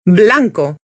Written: blanco

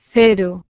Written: cero

